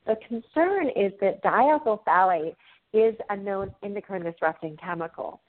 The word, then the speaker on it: phthalate: But it's spelled D-I-E-T-H-Y-L is diethyl, and phthalate is P-H-T-H-A-L-A-T-E, and it's sometimes abbreviated D-E-P, diethyl phthalate.